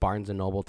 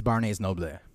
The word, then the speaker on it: Barnes & Noble: No one is going to like Barnes & Noble to read anymore. – First off, you actually said it wrong. It's Barnes & Noble.